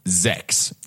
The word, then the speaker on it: X: Apparently the X in Guilty Gear X is pronounced as Zex. Z-E-C-K-S.